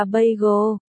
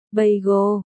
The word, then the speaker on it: bagel: Phải đọc là bagel, bà cẩu, bagel, chữ cẩu phải uốn lưỡi lên.